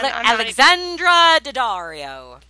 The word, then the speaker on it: Alexandra Daddario: Alexandra Daddario.